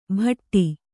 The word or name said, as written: ಭಟ್ಟಿ